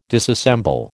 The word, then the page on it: disassemble: American English